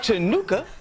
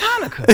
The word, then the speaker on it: Hanukkah: Turns out, it's not pronounced Chinookah. It's Hanukkah, and there's a whole race of people that have been celebrating it for thousands of years.